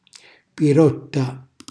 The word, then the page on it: pirotta: calabrese